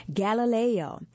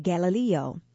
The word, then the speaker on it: Galileo: Galileo, pioneer. I said Galileo because it's pronounced both ways. If you've heard it both ways, Galileo, last name.